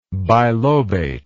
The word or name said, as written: bilobate